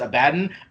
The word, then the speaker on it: Abaddon: We got ahead of that in our book club when I was like, I'm going to say Abaddon, even though I know some people pronounce it Abaddon.